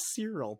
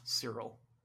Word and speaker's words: Cyril: We got to talk about Serial Karn. – Serial Karn? – Isn't that his name? – It's Cyril, but yeah, he eats cereal, so. – Cyril.